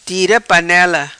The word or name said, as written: Tira Panela